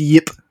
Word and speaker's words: Ip: Like you mentioned the Ip Man movies. I'm trying to pronounce it the way the Chinese people do. It's not Ip, it's Yip. Like yippee-ki-yay, motherfucker.